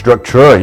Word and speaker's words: Structura: Am I pronouncing it right by any chance? – Structura, you got it. – Structura, okay, perfect.